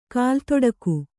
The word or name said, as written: ಕಾಲ್ದೊಡಿಗೆ